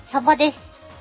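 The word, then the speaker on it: 鯖: お、魚編に青と書いて、サバです。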